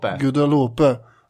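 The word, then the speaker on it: Guadeloupe: Han hade fått A-gruppen Guadalupe. Jag vet inte hur man uttalar riktigt. – Guadalupe. – Ja, just det.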